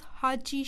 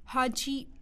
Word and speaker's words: Haji: My name is Haji Shah. – Can you spell that for me, please? – Yes, that's Haji, H-A-J-I, Shah, S-H-A-H.